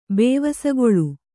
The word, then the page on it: ಬೇವಸಗೊಳು: Kannada